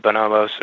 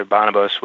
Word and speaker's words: Bonobos: That's a that's a very very good point, and you know the the the Bonobos or Bonobos, which whichever you prefer to pronounce it, they've had you know they've had a relationship with Nordstrom for a long time.